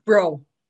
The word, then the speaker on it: Bro: Bro. – Bro. Okay, I would have pronounced it wrong. So even though it's spelled differently, you'll see in the show notes, it's Bro.